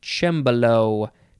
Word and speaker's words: cembalo: Next is, it is spelled C-E-M-B-A-L-O. It is pronounced cembalo, cembalo, noun from circa 1801.